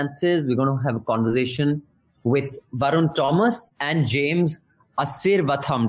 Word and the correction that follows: Asirvatham: It's Asirvadam. – Asirvadam. It's like Ashirvad.